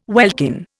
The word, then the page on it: welkin: Indian